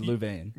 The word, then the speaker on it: Louvain: Or is it Louvain? – You can say Leuven if you're a Dutch speaker, and you can say Louvain if you're French, and this is somewhat contentious with the people there because for centuries, in fact, French was the language of education.